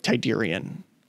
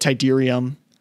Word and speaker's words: Tydirium: and it's pronounced both ways. I understand the way that it's spelled ends with an M, but it sounds like Tydirium when it's pronounced in the film. Like Han Solo will say it, and he'll say it Tydirium with an M sound, but then the Imperial officer will say it back to him, and it definitely sounds like it has an N sound.